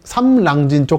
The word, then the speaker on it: 삼랑진: 그 아래쪽에 창원, 밀양, 김해가 만나는 삼남, 삼남진, 삼랑진 쪽도 이렇게 피해가 큰 걸 알 수 있고요. 삼랑진 발음 어렵습니다. 매번 할 때마다 삼랑진이라고 또박또박 말씀드려야 되죠. 그 이 아래쪽은 김해 진영 쪽, 위는 한때 동남권 신공항이 들어올 예정지였던 밀양, 상남, 하남 지역이고요.